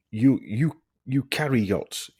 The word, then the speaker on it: eukaryotes: and ncRNA found in the eukaryotes, yeah, that's what it's pronounced.